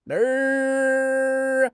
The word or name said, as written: na3 r5